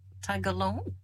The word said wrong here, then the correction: Tagalog: Tagalog, yes. It's Taga.